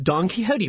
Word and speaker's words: Don Quixote: Don Quixote, which is pronounced Don Quixote by Miguel de Cervantes.